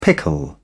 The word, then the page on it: pickle: British English